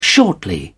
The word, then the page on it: shortly: British English